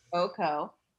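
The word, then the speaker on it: Cuoco: Kaylee Coke. I never can pronounce her name. – Coco.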